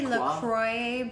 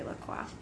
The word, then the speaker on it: La Croix: Yeah, people say La Croix, but, you know, being bilingual, it's actually La Croix.